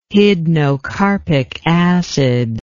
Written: hydnocarpic acid

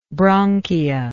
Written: bronchia

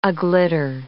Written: aglitter

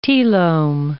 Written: telome

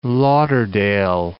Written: Lauderdale Lakes

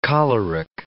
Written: choleric

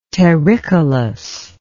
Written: terricolous

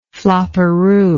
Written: flopperoo

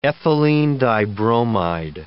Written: ethylene dibromide

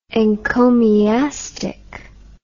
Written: encomiastically

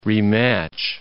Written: rematch

